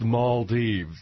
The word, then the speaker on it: Maldives: It's pronounced Maldives.